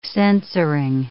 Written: censoring

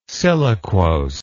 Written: siliquose

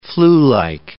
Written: flu-like

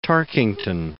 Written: Tarkington

